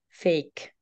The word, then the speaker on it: PHEIC: die englische Abkürzung spricht sich aus, dieses P-H-E-I-C, Fake.